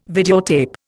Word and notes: videotape: Indian

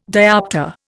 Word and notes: dioptre: Indian

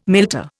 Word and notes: milter: Indian

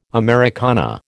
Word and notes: Americana: American English

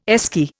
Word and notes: Esky: Indian

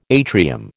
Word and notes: atrium: American English

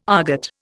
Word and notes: aargot: Indian

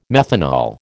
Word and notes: methanol: American English

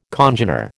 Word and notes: congener: American English

